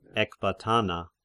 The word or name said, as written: Ecbatana